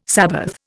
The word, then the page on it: sabbath: Indian